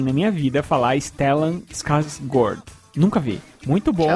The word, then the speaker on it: Skarsgård: E esse A com a bolinha quer dizer que se pronuncia com um O. Então, mais ou menos seria Skarsgård.